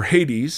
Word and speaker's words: Hades: In the New Testament, you have a word called Hades, or Hades, sometimes it's pronounced, that is the Greek equivalent of Sheol.